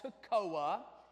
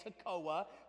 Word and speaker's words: Tekoa: And he was from a little farming town called Toccoa. And it's pronounced the same as a little town up in northeast Georgia, Toccoa Falls. So T-E-K-O-A, Toccoa.